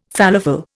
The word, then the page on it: falafel: Indian